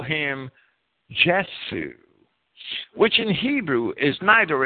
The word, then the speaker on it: Jesu: Jesu would be the proper German pronunciation of J-E-S-U, signifying 316.